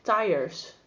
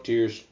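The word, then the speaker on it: tiers: And when I saw that, I thought it was pronounced tires. – Hmm. Nope, tears.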